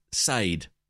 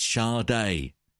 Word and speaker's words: Sade: And they said, it's Sade. And if they had put the little accent on the E, you would have picked up on that, but they didn't.